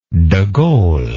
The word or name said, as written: degaulle